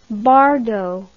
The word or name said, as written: bardot